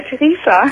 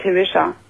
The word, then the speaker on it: Theresa: Nein, nicht Theresia, Theresa. – Theresa! Na, das ist aber ein Name, den kenne ich ja gar nicht. Theresa. – Ja, der wird normalerweise Englisch ausgesprochen, also halt Theresa.